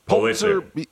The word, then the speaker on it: Pulitzer: He's a Pulitzer. – Pulitzer.